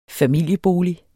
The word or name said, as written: familiebolig